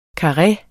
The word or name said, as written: KARREERNE